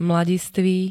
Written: mladistvý

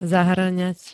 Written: zahŕňať

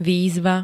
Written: výzva

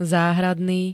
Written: záhradný

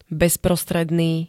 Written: bezprostredný

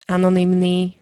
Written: anonymný

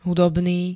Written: hudobný

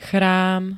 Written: chrám

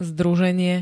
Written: združenie

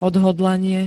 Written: odhodlanie

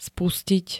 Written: spustiť

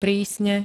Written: prísne

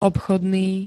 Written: obchodný

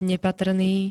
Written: nepatrný

